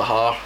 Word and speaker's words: haar: Came across the Har. – The what? – The har. – How do you spell that? – H-A-A-R.